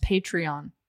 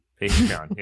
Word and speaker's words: Patreon: I think that's pronounced Patreon. – Patreon, yeah, yeah.